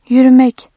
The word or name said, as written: yürü-